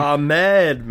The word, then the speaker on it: Ahmed: Ahmed, man. Ahmed. – Ahmed. – Come on, Ahmed. – We grew up in Silicon Valley. How do you not know Ahmed? – Oh, don't.